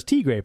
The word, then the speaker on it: Tigre: It says Tigre, pronounced Tigre, Spanish for tiger, natch.